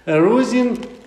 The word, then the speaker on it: Erusin: Also die Verlobung heißt Erusin oder Erusin, immer zweite, ne? Zweite Vokal, Betonung, oder? Erusin.